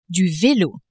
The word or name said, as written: velo